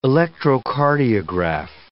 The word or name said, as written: electrocardiograph